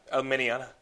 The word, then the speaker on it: Alminiana: Editor Scott Alminiana.